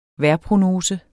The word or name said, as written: vejrprognose